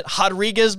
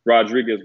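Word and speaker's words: Rodríguez: Rodriguez, by the way, that's like an H at the beginning.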